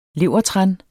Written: levertran

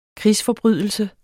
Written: krigsforbrydelse

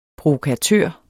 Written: provokatør

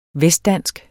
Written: vestdansk

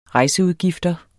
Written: rejseudgifter

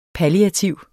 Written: palliativ

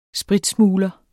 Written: spritsmugler

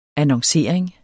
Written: annoncering

